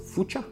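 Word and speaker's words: Fuchsia: но ее полностью обновили теперь на Fuxio, ну или, кстати, в американском правильно произносится Fucha, Fucha OS.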